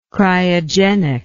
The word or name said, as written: cryogenic